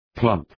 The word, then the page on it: plump: plʌmp